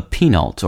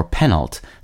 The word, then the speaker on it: penult: The second-to-last syllable in a word is called the penult, or penult, spelled P-E-N-U-L-T, or penultima, which literally means that which is almost last.